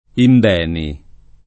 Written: Imbeni

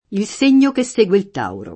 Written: tauro

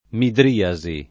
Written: midriasi

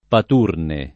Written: paturne

